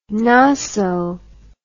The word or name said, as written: noso